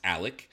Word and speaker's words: Alex: As soon as you type Alex, it's spelled with an X, but it's pronounced Alec.